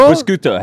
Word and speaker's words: prosciutto: Yeah, or prosciutto, how do you pronounce it? – Prosciutto, prosciutto. Spot on. – So, Australian way to say it.